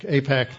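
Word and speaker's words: AIPAC: APAC, though, it's pronounced APAC, but APAC starts meeting.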